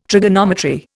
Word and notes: trigonometry: Indian

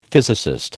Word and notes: physicist: American English

